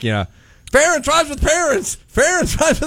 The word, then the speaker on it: Ferentz: Then, John, but, do you remember when the song came out, Ferentz Rhymes with Parents? – Yes.